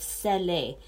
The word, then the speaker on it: scellé: It's scellé, S-C-E-L-L-E, and then it has an accent on the E. So scellé is the word sealed.